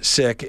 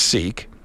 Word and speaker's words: Sikh: Stop saying sick, it's Sikh, except we had been told in our newsroom that a community leader for the Sikhs had said to pronounce it sick and not Sikh.